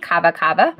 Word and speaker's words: kava kava: I'm going to go with kava kava. – You got it.